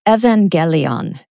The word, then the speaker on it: evangelion: In Greek, the underlying Greek word, I'm going to pronounce it kind of in English, evangelion.